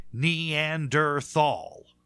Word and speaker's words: Neanderthal: So it has an H in it, and it is pronounced, and that's how it was defined. So it is and always will be Neanderthal.